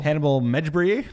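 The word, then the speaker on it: Mejbri: The next piece of news, Hannibal Medjbri. I can't pronounce that, sir. You want to give it a shot? Hannibal Medjbri? That sounds pretty good, sir.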